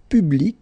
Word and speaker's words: public: Public. So you can see that you get this final C and then you pronounce it, hein? Public.